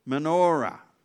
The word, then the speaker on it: Menorah: Some people draw it like there's a how do you pronounce it? Menorah? Menorah? What is it? Can't hear? Menorah. All right, the 7 branches on 1 stand.